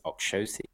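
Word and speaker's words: Oxóssi: They call it, apparently it's Oxossi. – Wow. See, this is news. Proper news to me. – Which might make sense because there's an accent on the, is it over the X? Or, no, the O, the second O in the name.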